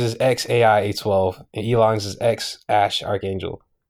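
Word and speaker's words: X Æ A-12: But Elon said the X is just X. The AE is Ash, because that's how you pronounce it in Greek. – Yeah, that's the one I heard. – And then A12 stands for Archangel, which was the Archangel 12.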